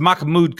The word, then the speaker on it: Mahmoud: Muhammad Khalil, is that how you say it? Either way. – Well, it's Mahmoud. $20 million. It's Mahmoud Khalil.